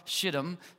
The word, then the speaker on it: Shittim: It says, early in the morning, Joshua and all the Israelites set out from Shittim. Again, that is how you say that.